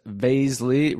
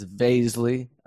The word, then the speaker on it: Vaszily: That's Vaszily, rhymes with paisley. Not Vaszily, not Vaszily, not Vaszily, it's Vaszily.